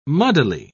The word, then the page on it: muddily: British English